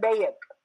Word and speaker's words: bayt: And I have heard in Jamaat, you know, people pronounce Ahlul Bayt as Ahlul Bayt. You know, it's not correct. Please distinguish these two words. Bayt, B-A-Y-T or B-A-I-T, is house. And Bayat with an I in it, B-A-I-Y-A-T, is to give allegiance.